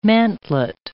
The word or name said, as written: mantelet